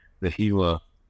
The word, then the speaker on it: Gila: It's called the Gila Country, G-I-L-A, and a lot of people mispronounce it.